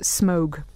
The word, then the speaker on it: Smaug: Interestingly, J.R.R. Tolkien apparently pronounced it Smaug. – But did he? – Apparently, yeah. Apparently he told the radio adaptation in the 70s that it was pronounced Smaug.